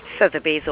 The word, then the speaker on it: Sotheby's: Sotheby's Auction House, yes.